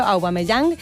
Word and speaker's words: Aubameyang: Esta vuelta a la Primera División que todo indica que contará con un delantero que se llama Aubameyang, perdón porque lo pronuncié mal al principio, Aubameyang,